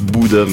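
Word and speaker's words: Bodø: Donc c'était on prononce Bouda, mais on va dire Bodo.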